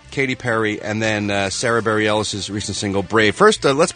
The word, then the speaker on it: Bareilles: Is it Barrier? – Bareilles. Sarah Bareilles.